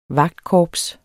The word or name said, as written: vagtkorps